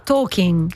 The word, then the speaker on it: Tolkien: noi lo chiamiamo Tolkien, in realtà si pronuncierebbe, pronuncialo tu. No, Tolkien sarebbe.